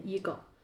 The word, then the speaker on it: ego: 我们还和朋友有过一些小小的讨论，它到底是单独分开念字母 e g o 呢，还是念 ego？官方给我们解读一下，到底怎么读它会更合适呢？ – 正规来说还是读 ego，然后它是来自于拉丁语，然后它是在英语里面其实是我的意思。